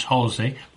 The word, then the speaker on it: Halsway: For those of you who are not familiar or are beyond our British Isles, the Halsey Manor is spelled H-A-L-S-W-A-Y, but pronounced Halsey Manor, for the National Centre for Folk Arts is actually tucked away in a beautiful spot.